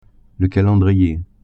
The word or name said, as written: calendrier